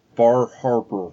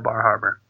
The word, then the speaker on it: Bar Harbor: Here I am at Bar Harbor. – Let me say that for you. Bar Harbor. – I pronounce the R's. There's distinct R's in there.